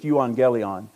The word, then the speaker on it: euangelion: So it's euon, if you pronounced it, if you listened to a pronunciation of the word, it's euangelion.